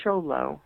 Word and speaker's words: Xolo: But it's spelled X-O-L-O and pronounced Sholo as a nickname for the breed.